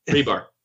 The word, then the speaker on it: Hribar: See, I didn't know this source, but do you know how to pronounce his last name? – Rebar. – Rebar? – Yeah. Rebar. – Okay, Rich Rebar.